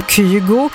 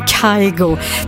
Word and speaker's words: Kygo: Höstens första tisdagkväll här på Mix Megapol, och du ska få killen som uttalas av norrmännen som Kygo, och lite fel då ifrån engelsmännen som kallar honom Kygo, tillsammans med Khalid och Griffin nu, Say My Love, ny musik här på Mix Megapol.